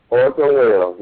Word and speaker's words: Orson Welles: Orson Welles, you got it.